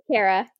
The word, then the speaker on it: Kara: Am I saying that right? Is it Kara? – Yep. – It's Kara. – No, it's Kara. You're right.